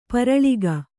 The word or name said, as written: ಪರಲೋಕ